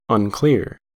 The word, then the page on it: unclear: American English